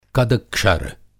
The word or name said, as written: कदक्षर